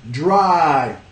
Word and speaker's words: dry: Dry, una sílaba. Dry, dry. De nuevo, si no pronuncian las dos consonantes, de ahí suena como die, morir, ¿ya?